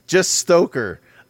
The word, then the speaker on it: Stoker: The first one is they're doing a Dracula movie based off of a single chapter from the Bram Stoker. Sorry, not Stroker. Don't Ooh. Stroker. Just Stoker.